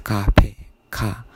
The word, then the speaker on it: cafe: First is because it's ca, so it's not ga, it's ka, it's cafe ka.